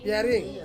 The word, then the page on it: piaring: Ansus